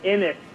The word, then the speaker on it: Innes: Yeah, and you know that guy Josh Innes, you pronounced his name wrong, by the way, Innes, he actually used to work for my current employer, Sports Radio 94 WIP.